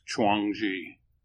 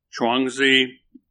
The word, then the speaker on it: Zhuangzi: The second Taoist to rise to fame was a man named, I hope I'm pronouncing it right, but I think it's Chuangzi, spelled Zhuangzi, but I think it's pronounced Chuangzi.